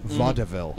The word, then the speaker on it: vaudeville: Which he pronounces with three syllables, vaudeville.